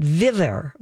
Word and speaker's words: Vivir: I hope I say it right. Vivir. – Yeah. – V-I-V-I-R.